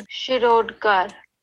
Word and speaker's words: Shirodkar: The following pronunciation is Shirodkar.